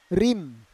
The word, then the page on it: rim: Roon